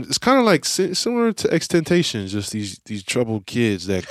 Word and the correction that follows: XXXTentacion: It was XXXTentacion.